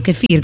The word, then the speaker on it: kéfir: O kefir, ¿cómo es kefir, que se pronuncia? – Ajá. – Kefir.